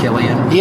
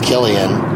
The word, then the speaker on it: Cillian: It's pronounced Cillian. – Yeah. And some people got so mad at me on some message board, just screaming about how, get it straight, it's Cillian.